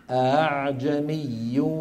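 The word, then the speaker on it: ءأعجمي: وفي رواية مفتوحة مسهلة وجها واحدا وهي الهمزة الثانية من قوله تعالى في سورة فصلت أعجمي وعربي، فيلفظها القارئ بين الهمزة المحققة والألف والمشافهة تحكم ذلك. أقول وقرأوا بعدي بعد إذنكم بصوت واحد أعجمي وعربي. الأخطاء التي تقع عند نطق الهمزة المسهلة، أولا تحقيقها كما ذكرت منذ قليل، ثانيا إبدالها هاء. نعم. أما تحقيق الهمزة المسهلة فصحيح على بعض القراءات، وأما إبدالها هاء فلا يصح البتة وهو خطأ محض.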